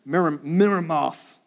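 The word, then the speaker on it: Meremoth: They laid its beams and set its doors, its bolts, its bars, and next to them, Miramoth. You have to say it like that.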